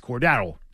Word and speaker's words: Cordarrelle: And we find out it's not. It's Cordarrelle. And hey, that's his name. You get it right. – Not Cordarrelle. – That's how it's pronounced.